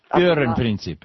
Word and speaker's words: Führerprinzip: And I've come across a link between Alito's stance on unitary leadership and, I hope I can pronounce it, the Führerprinzip. – Führerprinzip, yeah, which is that the Führer stands above the law, basically.